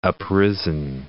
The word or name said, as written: uprisen